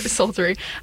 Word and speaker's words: psaltery: It's the silent P, I assume, but psaltery.